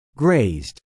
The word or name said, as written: grazed